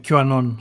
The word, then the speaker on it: QAnon: Ε, βρήκανε σπίτι και διάφοροι έτσι μήτρελοι στην Αμερική με τα ρεύματα αυτά τα QA, Canon, δεν ξέρω πώς να το προφέρω και σωστά. – Ναι, QAnon.